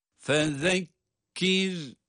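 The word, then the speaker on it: Fezekkir (فَذَكِّرْ): Fezekkir. Fezekkir. – Zal peltek. Peltek okunuyor zaten. – Fezekkir. – Sonra da cezimli olan ra kendisinden önce esri olması itibariyle ince okunuyor.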